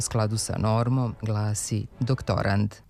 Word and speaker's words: doktorand: Za onog ko se sprema za doktorski ispit, ko je kandidat za doktorski stepen u nauci, kažemo da je doktorand sa krajnjim D, a ne T. Kako je reč o zvučnim parnjacima, nekad nije sasvim jasno koji smo glas čuli, ali kod akcenta nema dileme, ne bi trebalo da bude na poslednjem slogu.